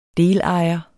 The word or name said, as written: delejer